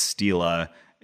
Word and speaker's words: stele: And I say stele. I think if you're pronouncing it the Latin way, it's stela. And plural stele, but I'm going to say stele and steles.